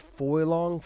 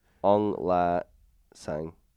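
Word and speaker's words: Aung La N Sang: Ung Lan. Oh, Sang. So you got it way off. – Yeah, I got it way off. Sorry, I was way off. – Ung La Sang.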